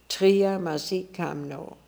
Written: Triamazikamno

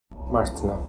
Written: Martna

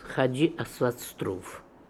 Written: Asvatz Troov Hadji

